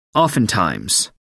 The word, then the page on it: oftentimes: American English